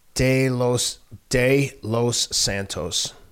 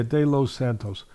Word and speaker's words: De Los Santos: That one's gonna be potentially a lot more competitive than you just said, because Stevenson's opponent, pronounce his name again, so I don't want to butcher it. – Stevens, Edwin De Los Santos. – What's the first part? – De Los Santos. – Yeah, De Los Santos. I want to say it right.